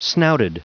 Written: snouted

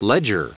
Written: ledger

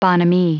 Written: bonhomie

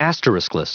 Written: asteriskless

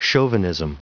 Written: chauvinism

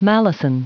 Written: malison

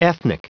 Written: ethnic

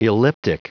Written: elliptic

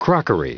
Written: crockery

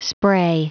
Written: spray

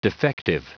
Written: defective

